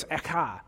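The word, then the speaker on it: echa (אֵיכָה): It's echa, is how you say it.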